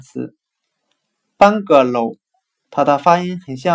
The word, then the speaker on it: bungalow: 今天所学的单词 “bungalow” 平房，我们就可以通过它的发音联想到汉语的“ 半个楼”，半个楼房，那就是平房了。 bungalow。